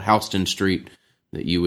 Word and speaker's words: Houston: Interesting how they say it's Houston there, you know? – Yes. – I think a lot of people would pronounce that Houston, but it is Houston.